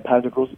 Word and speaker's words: Pantagruel's: Am I saying, is it Pantagruel's Nativity? Is that how you say it? – Yep, Pantagruel's. Yep, Pantagruel's Nativity.